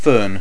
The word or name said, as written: fern